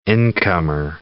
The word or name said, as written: incomer